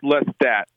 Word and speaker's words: Lestat: How do you pronounce? Is it Lestath? – So it's Lestat. Don't pronounce the H.